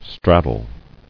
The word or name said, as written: Straddle